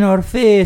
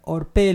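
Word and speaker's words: Orphée: Algunos dicen Orfe, es un apellido bastante complicado de pronunciar. Orpé, le voy a decir.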